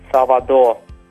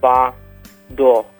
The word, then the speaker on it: Salvador: Salvador. Salvador. You'll notice the R was quite faint at the end. That is closer to the pronunciation that the locals have, as they pronounce the R as much softer.